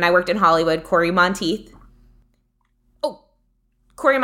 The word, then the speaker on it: Monteith: Monteith, like teeth.